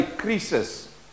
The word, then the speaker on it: krisis: In Afrikaans, we say krisis. It's spelled in Afrikaans just like that.